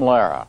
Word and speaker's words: Lehrer: It's the way he pronounces his own last name. – Good evening, I'm Jim Lara.